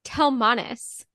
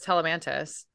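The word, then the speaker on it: Telemanus: Okay, I say Telemantis as well, but I was listening to a clip of the audiobook, and they were pronouncing it Telmanis. – Is that the graphic audio? Because the regular audio says Telemantis.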